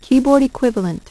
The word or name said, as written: keyboard equivalent